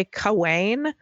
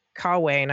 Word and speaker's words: Cawein: His name is Madison C-A-W-E-I-N. And I keep on wanting to say Cawain, but in my head, I'm going back to like my English major days and I'm like, Sir Madison Gawain.